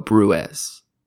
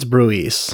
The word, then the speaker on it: Bruges: Peter of Bruges. Did I say it right? I'm going to struggle with this. – I think it's Bruges. – Bruges. Peter of Bruges.